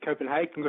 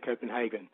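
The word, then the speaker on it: København: Well, I've always said Copenhagen because my Swedish colleagues always said Copenhagen. – Yes. – But given that it's spelt like Copenhagen, I checked before I called, and the Danish way is Copenhagen. – Horben. – Yeah, H-A-W. – So neither Hagen nor Hagen, but no. Horben.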